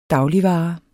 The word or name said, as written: dagligvare